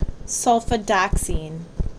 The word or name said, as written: Sulfadoxine